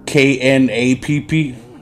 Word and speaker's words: Knapp: George K N A P P. Just call him Knapp, right? K is silent, like no.